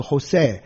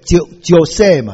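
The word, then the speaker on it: José: 那阿拉伯语是 Isa， Isa，那英文的耶稣哈，其实是拉丁文念嘛，不是这样，Jesus 那个 J，拉丁文念英文是 N H，对不对？San Jose。不是圣乔塞，对不对？Jose 那个 J 是念 H 嘛，是 Jose，不能念乔塞嘛，所以 Jesus 那个 G 不应该念 G 的嘛，所以 Jesus 应该是 H 的发音的话呢，比较像 Jesus。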